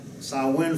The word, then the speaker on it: Samhain: Now, let me spell out Samhain for you, since it's a Gaelic word that's pronounced Samhain. It's spelled S-A-M-H-A-I-N. S-A-M-H-A-I-N. I would say Samhain, but it's a Gaelic word, and so it's actually pronounced Samhain. Now, Samhain was among the Celtics of Britain and Ireland, where they honored this god, the Celtics there.